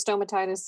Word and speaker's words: stomatitis: Yeah, you can either, I would say, the way I've heard it is stomatitis.